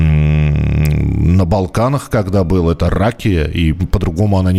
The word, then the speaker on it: ракия: А, ракия ударение? Нет, это неправильное ударение. Традиционно произносится ракия.